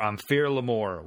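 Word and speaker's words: Am Fear Liath Mòr: And the name of the monster is the Big Gray Man, or the Gray Man, or Amphir Lamor, which is spelled Amphir Liathmor, because it's fucking Gaelic. I had to look up the proper pronunciation.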